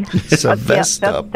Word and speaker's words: Sevastopol: Sevastopol.